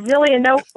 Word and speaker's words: Zelienople: Uh, is Zelienople, PA, and that is actually where Glade Run is located, where I work.